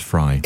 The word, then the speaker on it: Frey: Now, is it Fry or Frey? And I feel like I just escaped the Bowie thing, because as far as I'm concerned, it's David Bowie. – Yeah, and it's spelled F-R-E-Y, but it's pronounced Fry.